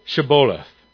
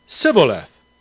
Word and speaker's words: Shibboleth: And if he said no, they would say to him, then say the word Shibboleth. And he would say, Siboleth, for he could not pronounce it right.